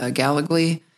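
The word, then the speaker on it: Gallogly: And since then, she's filed a federal lawsuit against the university, against then-President James Gallagly. Did I say that right? I did, didn't I? – You did.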